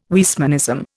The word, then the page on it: Weismannism: Indian